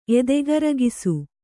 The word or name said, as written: ಎದೆಗರಗಿಸು